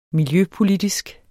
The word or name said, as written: miljøpolitisk